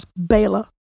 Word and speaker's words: Béla: Now, Baylor is spelt B-E with a little hat on it. So B-E-L-A, but he's from Hungary. So if you're speaking in Hungarian, that's Baylor.